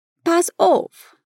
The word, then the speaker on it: pass auf: No Berlinerisch, são pronunciadas como se fossem um U. Pass auf.